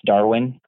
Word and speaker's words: Darwin: So that's Darwin, like the scientist, D-A-R-W-I-N, at Phytologix, which is spelled P as in Paul, H-Y-T-O-L-O-G-I-X.solutions.